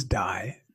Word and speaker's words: Dai: So my first name is Dai, D-A-I. Last name is Manuel, M-A-N-U-E-L.